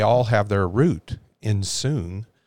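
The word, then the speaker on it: soon (σύν): But that word together, it in Strong's, it's 4862, and it's pronounced soon.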